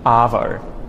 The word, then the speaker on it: arvo: Arvo, so that's a long R sound, and the AR is what tells me as a native speaker that vowel sound is long. So like when I pronounce words like car and bar, the R after the A, AR, tells me that it's a long vowel sound.